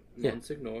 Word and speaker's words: Monsignor: Remember in the beginning of the book where they're like, hey, sorry. Monsignor. Senior, not senior. That's how it's pronounced.